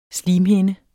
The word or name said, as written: slimhinde